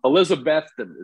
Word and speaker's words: Elizabethton: You played in, I don't even know how to say this, Elizabethton is how you would say it. – Elizabethton.